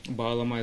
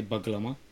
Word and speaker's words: bağlama: Bağlama is what usually people say Baglama, just the G is silent.